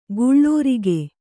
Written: ಗುಳೂರಿಗೆ

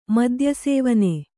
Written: ಮದ್ಯಸೇವನೆ

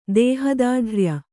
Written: ದೇಹದಾಢ್ರ್ಯ

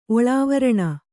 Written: ಒಳಾವರಣ

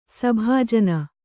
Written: ಸಭಾಜನ

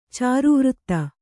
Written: ಚಾರುವೃತ್ತ

